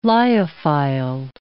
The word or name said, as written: lyophiled